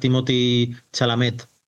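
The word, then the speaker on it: Timothée Chalamet: Pero claro, famosos que le dan glamour y prestigio al mundo del videojuego, porque al final, pues que salga Timothy Chalamet creo que a ver si lo he dicho bien.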